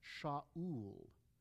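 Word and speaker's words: Shaul (שאול): The name Saul, in Hebrew, it's pronounced Shaul. Shaul.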